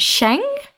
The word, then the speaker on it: Jean: what's spelled as Jean, but in the Cologne dialect it's pronounced Sheng.